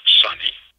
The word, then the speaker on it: SUNY: Operator pronounces it Sunny. It is Sunny at SUNY all the time.